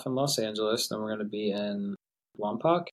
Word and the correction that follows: Lompoc: Yeah, September 7th, and then the 8th in Lompoc,